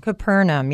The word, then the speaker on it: Capernaum: Capernaum? – Capernaum, yes. Capernaum. It's pronounced in quite a few ways.